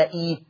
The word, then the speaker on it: ائت: أما لو أنا ابتديت بإئت بقى، احنا لما بنتعلم حفص بنقول ائت ولا ايت، زي ائتوني بقول ائتوني ولا ايتوني، بقول ايت زي ما بقول ايتوني.